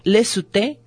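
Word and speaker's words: Lecouteux: Enciclopedia de magia y folclore nórdico y germánico, de Claude Lesuté, que se escribe Lecuteux, es otro.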